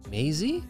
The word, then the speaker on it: Maisie: But it's called "The List" by Macy Peters, and it's Macy spelled the most untraditional way, M-A-I-S-I-E, like phonetically, like Macy. You can't pronounce. – Is that Maisie? – Maisie? – Maisie, which I think means like corny.